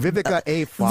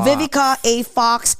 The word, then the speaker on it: Vivica A. Fox: Vivica A. Fox. – Vivica A. Fox and many more.